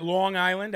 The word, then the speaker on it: Long Island: Long Island. That's how they say it there. Not Long Island. It's Long Island.